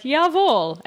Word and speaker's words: Jawohl: He writes, in your discussion on the use of German in Flash Forward, I cringe when you pronounce Jawohl. It is pronounced like Jawohl and is something you would expect to hear on Hogan's Heroes reruns or in the phrase Jawohl, mein Herr.